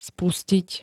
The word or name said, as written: spustiť